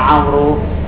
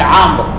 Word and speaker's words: عمرو: هاي الواو في عمرو اللي إحنا نتلفظها عمرو هذه تكتب ولا أقوى ولا تكتب الصحيح أن يقال عمرو